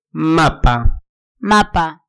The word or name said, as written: mapa